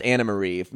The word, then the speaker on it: Anne-Marie: It turns out it's both, because it's spelled Anne-Marie, but it's pronounced Anna-Marie.